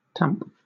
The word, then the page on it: tamp: British English